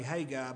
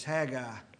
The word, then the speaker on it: Haggai: I used to say Haggai, but it's Haggai, like a hag. But we find over here in this prophecy, it's only two chapters long, but in Haggai and Zechariah, we're going to find that Haggai and Zechariah come to the children of Israel.